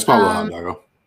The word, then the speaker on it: Pablo Hidalgo: And then in Star Wars Rogue One, the visual guide, Pablo Hidalgo, I hope I'm saying that right. – Yeah, it's Pablo Hidalgo.